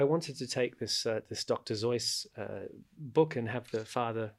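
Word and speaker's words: Seuss: And interestingly, I'm a huge fan of Dr. Zeuss. – Right. – A lot of people mispronounce it as Dr. Zeus, but it's actually Dr. Zeuss.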